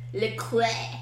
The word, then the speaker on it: La Croix: You say it properly, Le Croix.